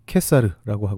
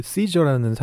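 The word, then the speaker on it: Caesar: 라틴어로 캐사르라고 하고 영어로는 시저라고도 발음하는 그 단어인데요. 옛날 우리 선조들이 성경에 음역을 할 때 그 단어를 가이사라고 그렇게 음역을 했습니다. 사실 우리가 잘 알고 있는 그 시저라는 사람은 캐사르라고 하는 사람은 로마의 황제는 아니었죠.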